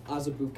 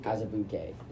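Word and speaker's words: Azubuike: I'm not sure if I'm pronouncing this right, but Udoka Uzubuki. – It's Azubuke. – Azubuke.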